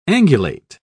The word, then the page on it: angulate: British English